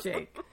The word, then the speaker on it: Jake: Also, apparently we've been saying Jack's name wrong the whole time. – Jake.